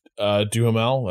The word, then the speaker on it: Duhamel: Josh Duhamel.